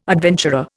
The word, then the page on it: adventurer: Indian